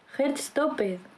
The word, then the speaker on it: Heartstopper: Heartstopper.